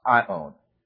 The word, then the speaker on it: aion: The word age is the word ion, A-I-O-N, pronounced ion.